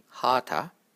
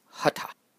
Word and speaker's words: hatha: Commonly, people make the first A long and say hatha, hatha, but this is not correct. It is hatha.